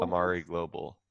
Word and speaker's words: Amare Global: Is it Amare? Is that how you pronounce it? Amare Global? – It's Amare Global. – Amare Global.